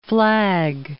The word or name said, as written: Flagg